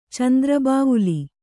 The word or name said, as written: ಚಂದ್ರಬಾವುಲಿ